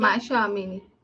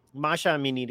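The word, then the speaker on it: माशा आमिनी (مهسا امینی): कैसे आमिनी बोलेंगे, कैसे बोलेंगे उसको? – माशा आमिनी. – माशा अमीनी, राइट.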